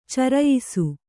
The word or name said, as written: ಚೌರಾಯಿಸಿ